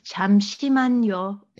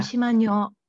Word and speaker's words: 잠시만요: Was it 잠시만요? – 잠시만요. – Yeah. – 잠시만요.